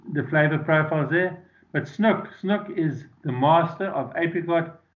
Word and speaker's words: snoek: And I need to remind anybody who's listening to me who is from America, there is a fish over here called snook. It's a very different species that are talking about. The one that we're talking about is spelt S-N-O-E-K